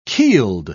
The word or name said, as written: keeled